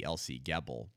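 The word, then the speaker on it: Else Gebel: I believe is how you pronounce it, E-L-S-E, Elsie Gebel. And she survived the Holocaust.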